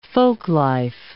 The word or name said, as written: folklife